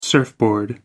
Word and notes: surfboard: American English

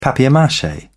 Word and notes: papier-mache: British English

